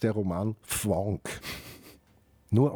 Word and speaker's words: Fvonk: gemeinsam mit der Susanne Höllier eine Sendereihe, die heißt Fwonk. – Fwonk dich frei. – Und Fwonk schreibt man F-V-O-N-K.